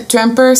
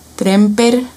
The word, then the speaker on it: Tremper: Ok, este Tramper se escribe e e T-R-E-M-P-E-R. Tramper. Longman, L-O-N-G-M-A-N.